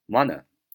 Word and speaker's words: wanna: 那么在口语当中啊，我们连读的时候，我们读作 wanna wanna。那比如说我想要就是 I wanna I wanna I wanna。比如说你想要就是 you wanna you wanna you wanna 啊，以此类推。我们造几个句子，比如说你想要去哪里啊？你想要去哪里？你要说。 You wanna go there?